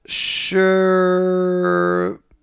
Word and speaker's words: Schermerhorn: It's it's pronounced Schermerhorn.